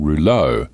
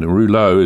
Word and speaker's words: rouleaux: So I'm just going to focus on the most common sign that I'm seeing at the moment, and it's called rouleau. And rouleau is spelled R-O-U-L-E-A-U-X, and it just basically means sticky blood. But rouleau is a French term, actually.